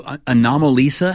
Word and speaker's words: Anomalisa: All right, number two, Anomalisa. Am I pronouncing that correctly? – I think you are, yes.